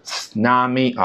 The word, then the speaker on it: tsunami: tsunami 啊啊 e u a o 啊，日语当中有个比较发啊奇怪的这个发音叫 u 啊，英文当中呢，它只能用相似的那个 u 啊来代替。